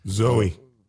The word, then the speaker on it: Zooey: which was done with Sam Rockwell and Zooey Deschanel and a couple other... – Zooey, actually. But that's cool, man. You just jump in my pick and correct my pronunciation.